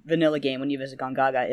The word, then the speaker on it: Gongaga: Another area that's ripe for sort of expansion for that sort of stuff is it Gongaga? Is that how they pronounce it? – Gongaga, yeah.